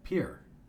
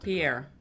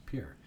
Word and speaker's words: Pierre: South Dakota, Amanda Bachman told me it's Pierre. – Not Pierre. – No, it's Pierre.